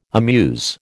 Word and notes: amuse: American English

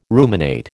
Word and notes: ruminate: American English

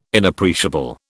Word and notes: Inappreciable: British English

